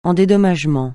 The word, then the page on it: en dédommagement: francais